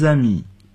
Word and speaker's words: amis: Jean et moi, nous sommes de vieux amis. – 我和 Jean 是老朋友了。复数形式的朋友就是在阿密后加上 s，但并不发音。